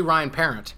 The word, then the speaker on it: Parent: Pronounces his name differently. It's actually Ryan Parent.